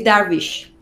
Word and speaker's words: Darvish: It is Darvish.